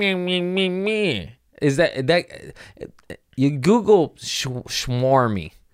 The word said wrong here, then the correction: smarmy: and it's his face did I say that right? Smarmy, I think.